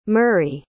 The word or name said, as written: murray